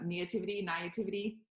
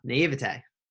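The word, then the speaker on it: naïveté: Naivety. – Naivete.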